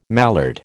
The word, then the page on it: mallard: American English